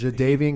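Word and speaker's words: Jadeveon: Yeah, he was saying Jadavian. – I don't know why. – He was reading them before. He's like, how do you say his name? Is it Jadavian? Like, there's a Jadavian. – There's a D in there.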